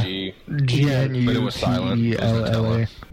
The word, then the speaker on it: Gnutella: Started with G. – Nutella. Nutella. Yeah. – What, Nutella? – It's actually pronounced Gnutella for Steven.